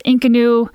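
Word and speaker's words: inconnu: I've heard it pronounced Inkanu.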